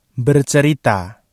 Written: bercerita

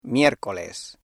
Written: miércoles

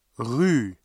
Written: rue

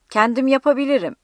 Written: kendim

